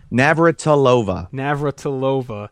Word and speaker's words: Navratilova: Navratilova.